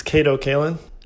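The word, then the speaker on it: Kato Kaelin: We have Kate Callen. Is that how you say your name? – It's Cato Kaelin.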